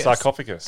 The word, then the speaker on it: sarcophagus: Say sarcophagus. – Sarcophagus. – There we go.